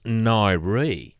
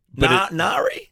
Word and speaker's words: Ngaire: It's pronounced Nyree. – Oh, Nyree. – Nyree. – Nari? – But it's spelt N-G-A-I-R-E.